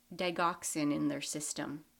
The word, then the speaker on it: digoxin: it's spelled D I G O X I N, so digoxin.